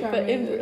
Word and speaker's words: Charmeuse: Oh, it's chamuse. Wait, what the heck? Charmeuse. Oh, wait, Charmeuse. But let's listen to the British pronunciation.